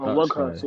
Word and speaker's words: Weghorst: Uh, Weghorst. – Oh, Weghorst.